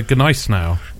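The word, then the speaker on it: Gniezno: But instead of the Briskovitsa, we are getting the Gneis now. – That's it. Pronunciation. Oh. Perfect, yes.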